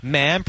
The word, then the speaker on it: ma'am: And subsequently, ma'am, pronounced with a short A as in jam.